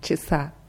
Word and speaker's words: часа: Bei 2 часа ist die Betonung auf dem ersten A, äh, auf dem zweiten A, часа, в 2 часа.